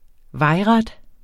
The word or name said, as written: vejret